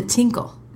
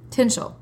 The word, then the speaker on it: tinchel: coming to the tinkle. That's how I read this. The tinkle, I said, surely it's not a tinkle. It's a tinchel.